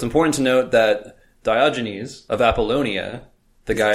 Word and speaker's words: Diogenes: Next up, I think I mispronounced Diogenes. It's Diogenes. I don't know what I said. – I think it's Diogenes.